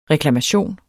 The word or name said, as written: reklamation